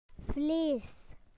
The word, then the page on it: meat: British English